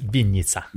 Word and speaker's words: Vinnytsia: He is a pastor of the Heart of Christ Church in Vinnytsia. Is that how you say it? – It's Vinnytsia. – Vinnytsia, I should have known that.